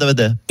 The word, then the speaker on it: Darmanin: Pardon, vous n'avez pas prononcé mon nom. Mon nom, c'est Gérald Darmanin. – Oui. – Darmanin, D-A-B-A-D-I-N.